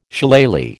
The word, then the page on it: Shillelagh: American English